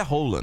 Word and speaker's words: Haaland: A pronúncia oficial do nome dele é Rola, né? – É, então, eu fiquei sabendo, fiquei totalmente constrangida.